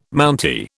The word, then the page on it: Mountie: British English